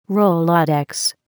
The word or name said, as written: rolodex